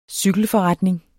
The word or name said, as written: cykelforretning